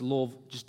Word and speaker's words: Louisville: It's Louisville. Really one and a half syllables, Louisville, Kentucky.